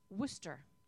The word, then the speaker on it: Worcestershire: Worcestershire is pronounced Worcester. – Not in my house. Where is it pronounced Worcester? – Worcester sauce. It's Worcester sauce.